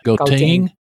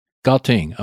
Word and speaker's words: Gauteng: Gauteng. – Gauteng.